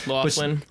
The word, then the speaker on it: Loughlin: Loughlin.